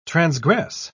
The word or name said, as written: transgress